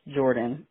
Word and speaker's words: Jordaun: J-O-R-D-A-U-N. It's Jordan. It's... my mom just put an extra letter in there to make it sound seem like it's a girl's name.